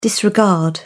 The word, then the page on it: disregard: British English